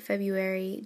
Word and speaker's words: February: Apparently, we say February just naturally rather than pronouncing it exactly how it's spelt.